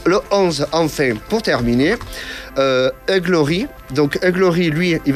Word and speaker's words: Hugh Laurie: Hugh Laurie.